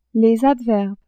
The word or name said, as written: adverbes